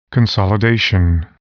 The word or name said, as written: consolidation